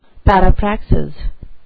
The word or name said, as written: parapraxis